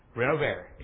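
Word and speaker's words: Renovaré: Now, I'm going to pronounce, this is a V, I'm sorry. I pronounce it Renovare, is that correct? Renovare. It's Latin. It is Latin for to restore or to renew.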